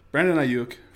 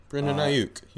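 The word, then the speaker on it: Brandon Aiyuk: Brandon Ayuk from Arizona State. Still can't say his name great. – Brandon Ayuk. You said it.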